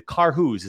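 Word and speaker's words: Karhu: But I would have never have known without coming in, talking to you and, you know, getting that proper assessment in the Carhu's.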